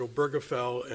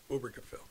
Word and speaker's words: Obergefell: The New York Times reported that he pronounces it Obergefell, and we all said that for a while, but I've actually heard him say it on YouTube, and it's Obergefell.